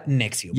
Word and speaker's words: NXIVM: Se escribe N-X-I-V-M. Voy a llegar a porque se llama así. – Por significa? Mamar. – Pero se pronuncia Nexium.